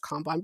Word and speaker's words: Kanban: Kanban.